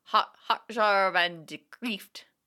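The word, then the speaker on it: Het jaar van de kreeft: So the J is always soft. I don't think there's a hard G sound. So hot jar. – Yeah. – Hot jar van de Kreeft.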